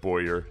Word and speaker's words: Bowyer: Forging quite a relationship with this Bowyer, however you pronounce it. It's spelled B-O-W-Y-E-R. – Yeah, I think Siri, when she reads these stories to me, pronounces it Boyer as well. So let's go with Siri's pronunciation.